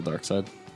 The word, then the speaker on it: Darkseid: So, like, he could, like, just send his entity into, the last intergalactic adventure has to do with Darkseid. – Darkseid? – Darkseid. – Darkseid. – Definitely spelled like seed. – It's still Darkseid.